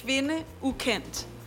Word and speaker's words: Kvinde Ukendt: E allora, Woman Unknown, what is the Danish pronunciation for the title? It's impossible. – It's Kvinde Ukendt.